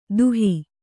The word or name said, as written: ದುಹಿ